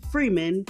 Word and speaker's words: Freeman: It is Lorena Freeman, L-O-R-R-I-N-A, last name, spell it just like it's said, F-R-E-E-M-A-N.